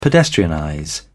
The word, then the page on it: pedestrianize: British English